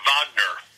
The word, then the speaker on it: Wagner: But her maiden name was Wagner, or Wagner, the way it's pronounced in German.